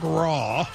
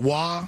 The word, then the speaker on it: Roy: Yeah, R-O-Y, but it's pronounced Wah.